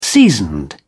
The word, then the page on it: seasoned: British English